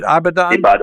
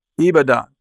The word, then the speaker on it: Ibadan: Is it Abidjan? – Ibadan.